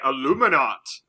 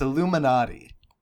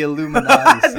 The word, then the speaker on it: Illuminati: Now, Jordan, I'm pretty sure you mispronounced yours. It's Illuminati. It's the Illuminati.